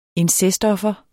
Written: incestoffer

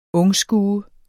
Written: ungskue

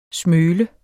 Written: smøl

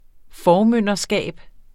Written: formynderskab

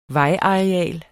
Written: vejareal

